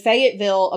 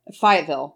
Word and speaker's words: Fayetteville: So this is from the Fayetteville Observer. – Fayetteville.